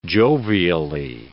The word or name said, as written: jovially